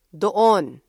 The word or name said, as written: doon